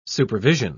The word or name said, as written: supervision